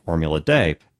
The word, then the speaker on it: Formula D: The letter D is pronounced day, which is where it gets the name Formula Day.